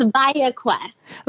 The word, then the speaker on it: ViaQuest: It's ViaQuest. – ViaQuest Psychiatric and Behavioral Solutions. Welcome. It is great to have you on. – Thank you. Yes, it is a mouthful. – Tell me about ViaQuest.